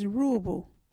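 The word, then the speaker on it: Ruubu: Our local Wadjuri word is Roobu.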